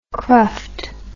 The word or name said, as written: cruft